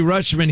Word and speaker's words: Rutschman: I believe it's pronounced Rutschman, but it's spelled. So his first name's Adley. – Okay. – R-U-T-S-C-H-M-A-N. Rutschman. But they say it like Rutschman, but it's probably Rutschman.